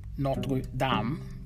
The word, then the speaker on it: Notre Dame: Kathleen Deignan is a sister of the Congregation of Notre Dame, or Notre Dame, the correct way of pronouncing it.